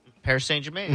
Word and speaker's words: Paris Saint-Germain: Paris Saint-Germain.